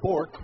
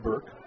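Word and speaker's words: Bourque: The name looks like Bork, which would be a great hockey name. It's B-O-U-R-K-U-E or Q-U-E, but it is pronounced Burke.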